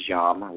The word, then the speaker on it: yam: which is yam, Y-A-M, and then you have the throat chakra, which is ham, H-A-M.